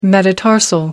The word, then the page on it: metatarsal: American English